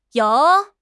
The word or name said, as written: you3